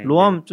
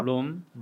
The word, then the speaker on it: Roam: 원래 있는 단어고 로암이라고 읽게 되어 있긴 아, 하네요.